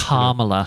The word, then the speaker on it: Kamala: And I think that Kamala Harris, I'm not sure exactly how you're supposed Kamala.